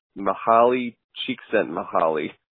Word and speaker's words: Mihaly Csikszentmihalyi: And incidentally, I want to say that I figured out how to say this guy's name correctly. It's Mihaly Csikszentmihalyi.